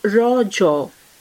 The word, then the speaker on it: Rajo: The festival is natively pronounced as Rajo, a local term that means menstruating woman.